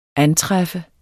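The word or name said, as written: ANTRÆFFER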